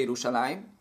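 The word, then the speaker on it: Yerushalayim (ירושלים): Nelle fonti Yerushalayim è scritto Yerushalem, senza la Yod verso la fine della parola, prima della Mem finale. In quanto Yerushalem, come viene chiamata nel Tanakh, anche se si legge Yerushalayim, secondo le fonti, questo era lo stesso luogo che poi diventa Yerushalayim.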